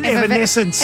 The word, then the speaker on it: Evanescence: He's the guitarist for Effervescence. – Evanescence. – What's the name of the band? Evanescence. – I'm saying it wrong. Say it again. – Evanescence. – That's it.